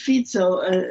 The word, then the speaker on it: Fico: His name is pronounced Fico, he's a social democrat.